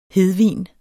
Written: hedvin

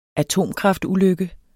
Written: atomkraftulykke

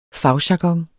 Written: fagjargon

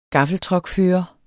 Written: gaffeltruckfører

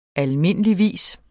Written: almindeligvis

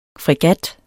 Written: fregat

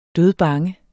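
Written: dødbange

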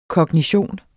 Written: kognition

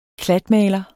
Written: klatmaler

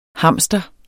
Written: hamster

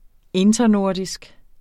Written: internordisk

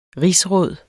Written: rigsråd

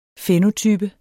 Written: fænotype

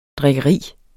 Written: drikkeri

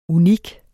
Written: unik

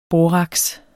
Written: boraks

